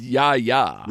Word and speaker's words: Yahya: It's Yahya.